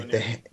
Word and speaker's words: Mjolnir: And then she ends up at New Asgard and we see Mjolnir. Did I say that right? Okay, Mjolnir.